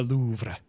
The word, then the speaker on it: Louvre: Yeah, it's the Louvre.